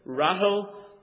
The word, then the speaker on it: Rahel: Rahel," really Rachel, same name. The CH, of course, is pronounced like the lock, like the Scottish people do. So it's really Rachel, Rachel.